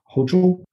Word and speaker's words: Hochul: Another fact that Gorsuch latched onto was, like I said, the governor, her name is pronounced Hochul.